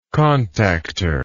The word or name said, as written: contactor